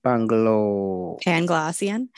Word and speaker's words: Panglossian: Panglossian.